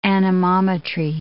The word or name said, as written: anemometry